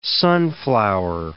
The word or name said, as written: sunflower